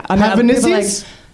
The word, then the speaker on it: Hazanavicius: Well, you learned how to pronounce Hazanavicius. That's pretty impressive. – It's taken me like 95 times. – Did I get it right? – Yeah. – Hazanavicius? – Yeah.